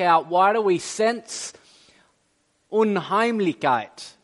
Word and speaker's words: Unheimlichkeit: it's the word unheimlichkeit. Is that right? I don't know, right? It's a German word, unheimlichkeit. Carmen, how do you say it? Unheimlichkeit. Woo, okay. And by that,